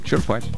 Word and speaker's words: черпать: Черпать. Ну, почему нет? На самом деле, вот словари все почти указывают совсем другое. Правильно ударение на первый слог. Этот вариант зафиксирован в большинстве нормативных словарей. Черпать на «е».